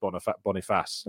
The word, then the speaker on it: Boniface: because Boniface of Bayer Leverkusen. – Okay, I was saying like Boniface and Boniface and things like that, but apparently it's just Boniface.